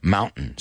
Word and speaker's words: mountain: Now, Americanos decimos mountains. Mountain, not mountain, right? Latino lo pronunciamos. We do like a glottal, right? Mountain. Say it with me, mountain. So they, if you want to say it like an American, mountain, fountain, certain. That's how we talk, right?